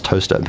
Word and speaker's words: Towcester: Is it similar to there being a town in Oxfordshire, which is spelt Towcester but pronounced toaster, toaster, as they drive past? – Yes, absolutely.